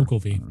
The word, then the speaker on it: Ogilvy: Is that how you say his name? Ogilvy? Make sure. – Ogilvy. Yeah. – Yep.